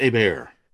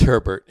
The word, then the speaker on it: Herbert: Is it Herbert or is it Hebert? – It's Herbert.